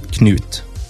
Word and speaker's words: Knut: Yeah, I think, a lot of English people or English-speaking people sort of forget the K and it's nut, right? Or not, but it's actually K with Knut.